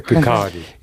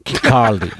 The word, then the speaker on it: Kirkcaldy: Se pronuncia Kirkcaldy. – Kirkcaldy.